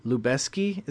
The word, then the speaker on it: Lubezki: It was shot by Lubezki. Is that how you say it? – Yeah, that's how you say it.